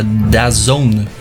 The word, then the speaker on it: DAZN: Ah, vamos falar de um bem legal agora, que é o da, da Zone. É assim que pronuncia, mas se escreve D-A-Z-N, tudo maiúsculo. D-A-Z-N, da Zone e é, esse é interessante porque ele é, é uma coisa que é só para esportes.